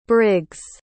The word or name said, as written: Briggs